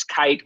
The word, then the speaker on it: Kyte: It's Kite. We spelled it wrong intentionally because we figured teachers would love that, right? It's spelled K-Y-T-E.